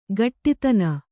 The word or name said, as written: ಘಟ್ಟಿತನ